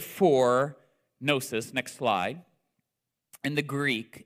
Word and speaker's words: gnosis: The Greek word for knowledge here is the word gnosis. The G is silent.